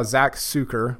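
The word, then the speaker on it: Zach Sucher: So last week we saw Zach Sucker, I think I'm pronouncing that correctly. – That's correct.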